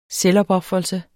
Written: selvopofrelse

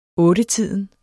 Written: ottetiden

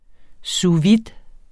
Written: vide

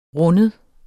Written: rinde

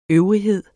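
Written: ØVRIGHEDERNES

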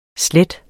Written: slette

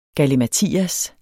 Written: galimatias

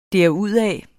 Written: derudad

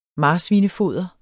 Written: marsvinefoder

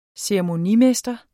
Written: ceremonimester

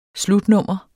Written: slutnummer

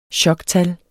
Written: choktal

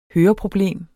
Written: høreproblem

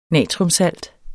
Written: natriumsalt

